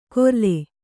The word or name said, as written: ಕೊರಲೆ